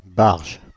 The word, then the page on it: nut: /nʌt/